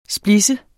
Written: splidse

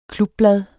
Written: klubblad

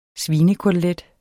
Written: svinekotelet

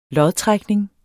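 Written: LODTRÆKNING

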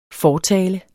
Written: fortaler

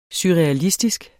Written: surrealistisk

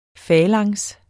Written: falanks

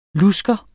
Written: lusker

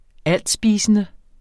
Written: altspisende

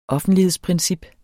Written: offentlighedsprincip

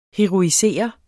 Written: heroisere

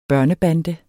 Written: børnebande

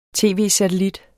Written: tv-satellit

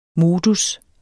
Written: modus

